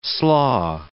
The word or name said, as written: slaw